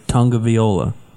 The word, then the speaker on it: Tagovailoa: You keep saying tag. It's not. It's Tagovailoa.